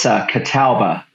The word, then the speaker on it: Catawba: The Catawba, is that right? Catawba Indian Nation, is that right? Am I pronouncing that correctly? – It's Catawba Indian Nation.